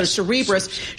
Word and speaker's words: Cerebras: How do you say your name? Like Cerebral. So Cerebras shares soaring.